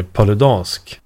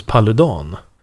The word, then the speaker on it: Paludan: Jag sa ju tidigare Rasmus Paludan. Fick jag höra sen att jag var riktigt dum i huvudet som sa så. För han heter ju Paludan. Rasmus Paludan. Men Kasselstrand ger en ledtråd. För han uttalar namnet på ett annat sätt. Han säger Rasmus Paludan. – Ja, det låter ju danskare faktiskt. – Exakt, det är lite dansk, det är lite danskt här. Så istället för att det är liksom A-U-A-vokalerna så är istället Ä-Ä-Ä.